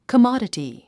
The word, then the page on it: commodity: kəmάdəti; カマダティ